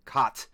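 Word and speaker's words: Kät: Uh, well, the way I have it spelled here is K A with an umlaut T. Cot.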